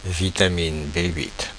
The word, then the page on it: vitamine B8: France (Paris); /vi.ta.min be ʔɥɪt/